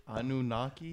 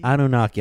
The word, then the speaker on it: Anunnaki: I'm one of those uh, uh, Anuki. How do you pronounce that shit? Anunnaki. Anunnaki.